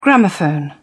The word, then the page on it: gramophone: British English